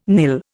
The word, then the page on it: nil: Indian